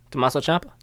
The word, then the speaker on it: Tommaso Ciampa: Tommaso Ciampa? – Thank you.